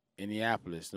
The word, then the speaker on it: Indianapolis: What about people from Indianapolis? You know what I mean? Like, St. Paul. – I'm sorry, I gotta say this. Indianapolis. You said like you didn't pronounce the D.